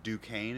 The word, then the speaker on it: Duquesne: It's like C-O-W. Enga. – And then there's also another fun one for me is I think it's Duquesne is how you actually pronounce it, but it's spelled like Des Quayi.